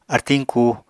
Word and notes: artiñ ku: Apatani

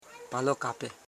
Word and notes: palokape: pa.lo.ka.pe

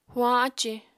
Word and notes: huañ ácì: apatani

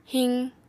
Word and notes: hiq: Apatani

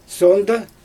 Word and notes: soondɯ: Xyzyl

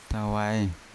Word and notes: tawae: Yawa

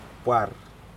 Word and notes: par: Aneityum

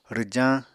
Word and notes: rwjañ: Apatani

